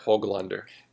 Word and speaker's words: Höglander: Another name I know that I think this was Adam Vingan's pick in the Athletic mock draft was Nels Höglander. – Höglander.